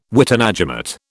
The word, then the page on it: witenagemot: British English